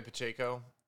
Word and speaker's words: Pacheco: How do you say his name? – Yeah, Zaya Pacheco.